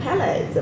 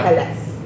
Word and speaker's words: Pelles: We're joined by my co-host, Daniel, and today's guest, Dan Pelles. Am I pronouncing that correct? – Pelles, yeah. – Pelles.